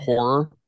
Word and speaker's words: HO99O9: First one, musically, I recommend that you go check out Horror. Literally, it's spelled H-O-9-9-O-9, and it's like horror hip hop mixed with a little bit of like trap.